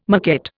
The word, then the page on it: maquette: Indian